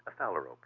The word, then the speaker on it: phalarope: and correct my pronunciation, a phalarope? – A phalarope, yes.